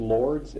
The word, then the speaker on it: Lourdes: In 1958, a wealthy aunt from Savannah sent her and her mother to the baths at, how's it pronounced, Lourdes, L-O-U-R-D-E-S.